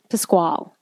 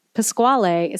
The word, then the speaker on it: Pasquale: It's Italian, and that's what it should be. Pasquale is how it should be pronounced. But Pasquale, there's an E on that.